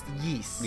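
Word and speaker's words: Ys: It's, I've heard it's pronounced Yeast. – Yeah, the only reason I know is because of Minus, but yeah, I believe it's Yeast.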